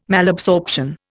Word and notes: Malabsorption: Indian

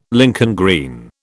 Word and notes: Lincoln green: British English

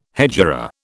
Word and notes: hegira: British English

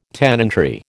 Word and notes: tenantry: American English

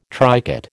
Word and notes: tricot: American English

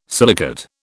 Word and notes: silicate: British English

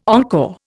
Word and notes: encore: Indian

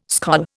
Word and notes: skol: Indian

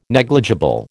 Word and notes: negligible: American English

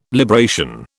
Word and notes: libration: British English

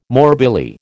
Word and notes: morbilli: American English

